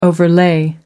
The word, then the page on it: overlay: American English